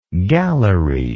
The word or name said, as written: gallerylike